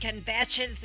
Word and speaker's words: kvetch: Who just blames and shames and kvetches and, how do you say it? – Kvetch. – Kvetch. – Yeah. – Then the K is not silent.